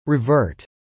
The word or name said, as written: revert